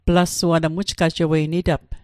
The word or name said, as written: Plasua